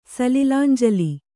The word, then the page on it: ಸಲಿಲಾಂಜಲಿ: kannada